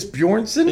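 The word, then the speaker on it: Björnsson: Is it like half-poor Julius Bjornsson? – Yeah, it's Bjornsson, I know, is how you say his last name.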